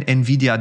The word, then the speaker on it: Nvidia: Die Anforderungen steigen immer weiter und Nvidia ist dann ein Partner dieser Plattform ähm, und wenn Nvidia drinsteckt, ist mehr möglich.